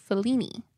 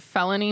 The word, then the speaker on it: Fellini: And the studios would later be used by many Italian producers, including Federico Fellini. – Who that? – I don't know. – Just wanted to say his name, huh? – What was that joke? Someone pronounced it Felony, Mr. Felony is here.